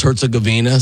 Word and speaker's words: Herzegovina: And for those of you who want, it's pronounced Herzegovina, so the U.S.